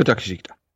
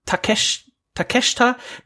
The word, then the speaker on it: Takeshita: Konosuke Takeshita. – Takeshita hinten wurde es eben nicht ausgesprochen. – Nee, so wie Shinsuke, ne? – Ja, Takeshita.